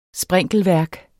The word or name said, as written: sprinkelværk